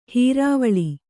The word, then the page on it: ಹುರುವಳ್ಳಿ: huruvaḷḷi